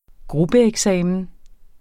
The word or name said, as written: gruppeeksamen